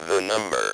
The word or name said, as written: the number